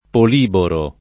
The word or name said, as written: poliboro